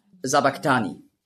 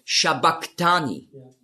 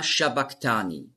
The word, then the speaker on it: Sabachthani: Sabachthani lesen viele. Aber das heißt eigentlich auf Aramäisch ausgesprochen ist es Shabachthani. Und das ist das CH, das Chi, ist im Griechischen als K ausgesprochen worden. Aber das S steht eben für den Sch-Laut.